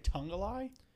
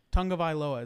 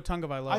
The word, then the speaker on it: Tagovailoa: You say Tungalai? – Tagovailoa is how you say it. – I didn't see an N in the name. – It pronounced like that. – It's Tua Tagovailoa.